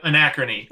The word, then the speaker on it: Anachrony: I don't know if I'm going to pronounce this right, but tell us about Anachrony. – Yep, Anachrony. – Anachrony. – Close. Yep, at least that's how I pronounce it.